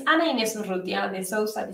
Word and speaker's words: Ana Inés Urrutia de Sousa: This is how you say my name, just for everyone wondering. But it's not that different. It's the R that it complicates things for you, I think.